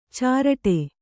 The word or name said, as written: ಚಿರಡಿ